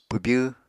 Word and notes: pwbyw: apatani

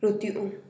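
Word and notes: rutyu'un: Teotitlán del Valle Zapotec

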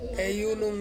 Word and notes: unum: Ansus

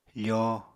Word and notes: foo: apatani

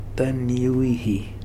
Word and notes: Taniuihi: Futuna

